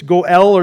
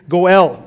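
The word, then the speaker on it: Goel (גֹּאֵל): It's Goel, or there's some different ways of pronouncing it. Goel.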